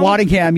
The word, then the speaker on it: Waddingham: How do you pronounce the last name? Is it Waddingham? – Waddingham, yes.